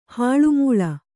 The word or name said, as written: ಹಾಳುಮೂಳ